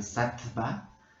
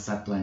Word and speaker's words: Satva: Entonces, Satua se escribe, se escribe S-A-T de Tito, V, ¿no? Este, A. Esa es la palabra Satua, o sea, se podría leer en español Satva pero se pronuncia Satua en sánscrito. Satua, yoga, pues yoga, Y-O-G.